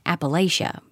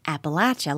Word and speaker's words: Appalachia: He pronounces it Appalachia, by the way. Listeners of our show know that I pronounce it like Vance's Kentucky family says it, Appalachia, like throwing Appalachia.